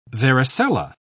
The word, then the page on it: varicella: British English